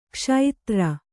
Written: ಕ್ಷುದ್ರ

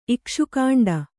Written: ಇಕ್ಷುಕಾಂಡ

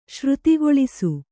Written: ಶ್ರುತಿಗೊಳಿಸು

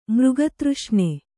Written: ಮೃಗತೃಷ್ಣೆ

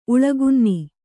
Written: ಉಳಗುನ್ನಿ

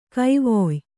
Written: ಗವಯ

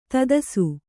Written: ಧೂತಿಸು

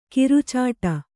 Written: ಕೊರಚಾಟ

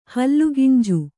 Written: ಹಲ್ಲುಗಿಂಜು

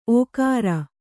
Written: ಓಕರ

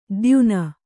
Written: ಧ್ಯಾನಿ

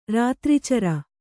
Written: ರಾತ್ರೀಚರ

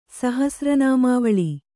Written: ಸಹಸ್ರನಾಮಾವಳಿ

